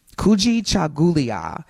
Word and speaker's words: Kujichagulia: Kujichagulia, which also means self-determination.